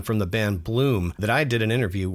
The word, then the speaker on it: Bluhm: That is spelled B-L-U-H-M, Bloom.